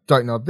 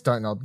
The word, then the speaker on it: Dontnod: So yes, the name hasn't changed because we've all been saying it wrong. So before every podcast I've recorded, you would have heard me say, don't nod this, don't nod that. That's exactly how you say the name now.